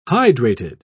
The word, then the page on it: hydrated: British English